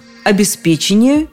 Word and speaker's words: обеспечение: Обеспечение и только так. Поддержка у меня была солидная. За ударение обеспечения были как минимум три словаря.